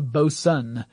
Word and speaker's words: boatswain: that's a boson, which is actually spelled like boatswain, and that has nothing to do with particle physics unless you're talking about very tiny boats.